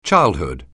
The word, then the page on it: childhood: English